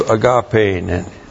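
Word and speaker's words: Agape (ἀγάπη): Tein heautou agapein. This is His own love. Agape is the key here. Agape. A-G-A-P, long E. Agape.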